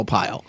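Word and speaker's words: Arpaio: It rhymes with Scott Baio. It's like Alpaio.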